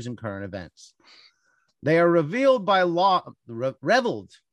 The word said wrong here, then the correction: reviled: Is that reveled is pronounced correct? – Reviled. They're reviled. – It is reviled.